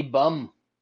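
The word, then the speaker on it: Böhm: Mandy, I learned this from our boys over at the Dogger Pass podcast, but correct pronunciation, Mandy Bum taking on Victoria Leonardo.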